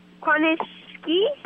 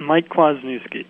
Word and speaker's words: Kwasniewski: This is Mike Kwasniewski. – Kwasniewski. – How do I say your name? – Yep, Mike Kwasniewski.